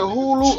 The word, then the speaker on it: Hulu: Is it Hulu or Hululu? – Nigga, that... My nigga, how do you say that shit? The green shit. The H-U-L-U shit. – Yep, Hulu.